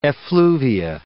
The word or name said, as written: effluvia